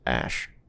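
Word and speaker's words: X Æ A-12: X and then A-E. They pronounce it Ash.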